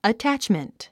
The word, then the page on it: attachment: ətǽtʃmənt; アタァッチメント